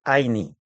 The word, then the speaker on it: Ayni: Ella me dijo, te voy a regalar una palabra quechua, que es Aini. Se escribe Ayni.